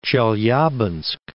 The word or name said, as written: Chelyabinsk